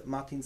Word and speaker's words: Martin: Früher habe ich mich immer gern vorgestellt mit, ich heiße Martin Skripczak, das schreibt man M-A-R-T-I-N, und Skripczak, wie man es spricht.